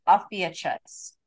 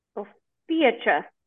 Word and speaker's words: Ophiuchus: So there's a 13th and it's Ophiuchus. I'm saying that wrong. – Ophiuchus.